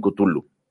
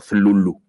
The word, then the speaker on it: Cthulhu: Em, siempre que hablamos o se menciona Lovecraft, pensamos inmediatamente en Cthulhu o Zlulu, como él decía que se pronuncia.